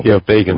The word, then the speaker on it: Begin: Begin.